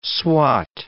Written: swot

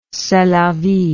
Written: c'est la vie

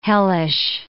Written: hellish

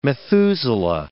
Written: Methuselah

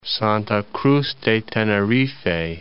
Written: Santa Cruz de Tenerife

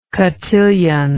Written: cotillon

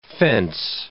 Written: fence

